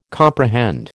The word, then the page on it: comprehend: American English